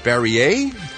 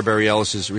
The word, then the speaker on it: Bareilles: Is it Barrier? – Bareilles. Sarah Bareilles.